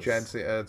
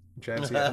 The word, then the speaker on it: Jadzia: Is the name Jedzia? Wait, how do they spell that? I'm curious. – Jedzia, it's JADZIA. – Like Jedzia. – from Star Trek? Jedzia. Am I the only one who's gonna get that reference? Like Jedzia Dax from DS9?